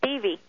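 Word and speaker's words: Devvy: It's a long E and pronounced Devi.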